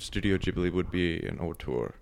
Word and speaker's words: auteur: And you mentioned Martin Scorsese as a, how do you pronounce that, auteur? – Auteur, yeah. – The French term.